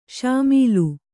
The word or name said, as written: ಷಾಮೀಲು